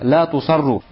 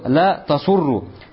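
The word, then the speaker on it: Ля тусарру (لا تصروا): Ля тасурру. Валь аввала сах. Но первое чтение является более правильным. Читается как именно «ля тусарру», а не «ля тасурру».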